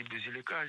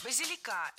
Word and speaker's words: базилика: Кстати, можно или базилика зеленого, или петрушку. – Базилика – это неверно. Правильный вариант – базилика. Ударение ставим на третий слог, и там его сохраняем во всех формах. Базилика, базилику, базиликом.